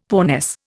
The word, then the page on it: poorness: Indian